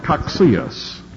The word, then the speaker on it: Cocceius: It was a man by the name of Coxius who really systematized covenant theology. His name is spelled C-O-C-C-E-I-U-S.